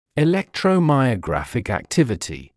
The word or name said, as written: electromyographic activity